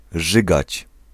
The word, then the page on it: rzygać: /ˈʐɨɡat͡ɕ/